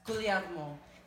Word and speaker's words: Guglielmo: My name is Emily Alexandra Guglielmo, and I say it, you have to do this, Guglielmo, because it is Italian and the second G is silent.